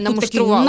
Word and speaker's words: намуштрували: А намуштрували, тут наголос, так. Намуштрували.